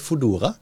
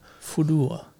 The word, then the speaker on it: Foodora: Jeg sier Foodora. – Foodora. For det er jo en fedora, bare til food. – Ja. – Foodora.